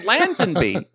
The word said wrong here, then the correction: Lazenby: Lazenby. – Lazenby. – Yes. – My God, I feel like an idiot. Man. I've been mispronouncing his name for so long.